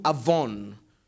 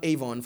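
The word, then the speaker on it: avon (עָוֹן): The word iniquity is the word in Hebrew avon. Not Avon, for those of you who sell it, but avon. Amen. Same spelling, different pronunciation.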